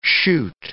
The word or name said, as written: chute